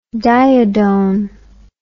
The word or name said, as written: diodone